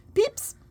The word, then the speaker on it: Pepys: Pepys? Is that how you say his name? – It's Pepys. It's P-E-E-P-S. Pepys.